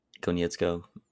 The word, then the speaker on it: Konietzko: For the record, it's Bryce Konietzko, I believe is how you say it. – Konietzko.